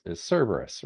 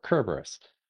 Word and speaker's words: Cerberus: The most famous of which, of course, is Cerberus, right, or Kerberos. I've got a weird thing about the fact that we've transformed the hard K sound in ancient Greek into its soft C's, and it drives me nuts. But you know, I'll use the word that people recognize, right?